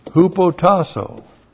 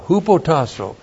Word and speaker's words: hupotasso: Hupotasso. H-U-P-O-T-A-S-S long O. Hupotasso.